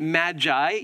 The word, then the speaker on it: Magi: The transliteration from the Greek is M-A-G-O-I. Magoi. Any of you ever seen the movie Gremlins? What were they called? The Magoi or something? Magoi, yeah. So it almost sounds like that, the Magoi, that old 80s movie, the Gremlins, okay? So we'll pronounce it Magi.